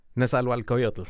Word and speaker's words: Nezahualcoyotl: Nezahualcoyotl.